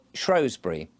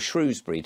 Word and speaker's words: Shrewsbury: of Shrewsbury, or Shrewsbury, depending on how you wish to pronounce it.